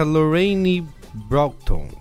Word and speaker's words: Lorraine Broughton: Charlize Theron interpreta Lorraine Broughton. É assim que se fala, a pronúncia? – É, tá certo, tá passável.